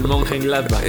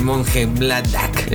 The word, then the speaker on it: Mönchengladbach: El Mönchengladbach. El Mönchengladbach.